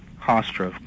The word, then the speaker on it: Hofstra: Was it Hoscoff University? I'm pronouncing it wrong. Hofstra, correct.